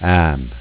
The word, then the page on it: Am: Dialetto